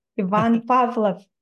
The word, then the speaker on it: Ivan Pavlov: And I love that you pronounce his name correctly, Ivan Pavlov.